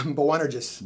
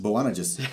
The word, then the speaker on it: Boanerges: Boanerges. – Boanerges.